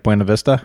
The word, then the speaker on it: Buena Vista: So, I had to train myself how to say this town because here in Florida, we say Buena Vista. – Yeah, like Disney, Lake Buena Vista. Mm-hmm. – But it's Buena Vista, it's how they pronounce it, I guess, in Michigan and Colorado.